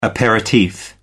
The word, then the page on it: aperitif: American English